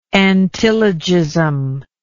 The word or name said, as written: antilogistically